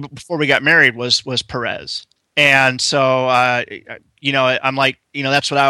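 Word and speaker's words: Pérez: But this letters page told me something that I didn't know up to that point, and that is the pronunciation of George Perez, accent on the first E. They actually had that in a little yellow thing by the editor. Instead of Perez, it's Perez.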